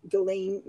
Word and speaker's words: Ghislaine: So Ghislaine, I guess the S is silent in her name. Ghislaine Maxwell trial starts on Monday.